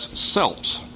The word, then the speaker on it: Celts: The English word C-E-L-T-S is pronounced sometimes with a soft C as though it were spelled S-E-L-T-S, Celts. Other times it's pronounced with a hard C as though it were spelled K-E-L-T-S. And guess what? Both pronunciations are correct.